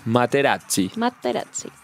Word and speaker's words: Materazzi: ¿Cómo se dice? – Materazzi.